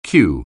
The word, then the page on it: cue: anglais